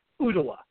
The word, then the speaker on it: Ooltewah: It's spelled OOLTEWAH, but I assure you it's pronounced Ottawa.